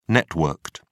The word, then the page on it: networked: British English